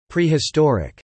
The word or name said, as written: prehistoric